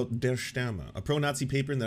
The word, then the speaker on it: Der Stürmer: How to pronounce Der Stürmer. Is it going to be Die Stürmer? – Der Stürmer. – Der Stürmer. That wasn't that far.